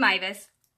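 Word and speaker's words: Meiwes: So, we're going to talk about Armin Mivis. It looks like it's spelled Miewis, but it's German. Armin Mivis.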